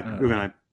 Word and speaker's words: Guggenheim: Yeah, Guggenheim.